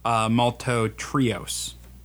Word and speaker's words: maltotriose: And then finally, maltotriose. Did I say that right? – Yes, you did.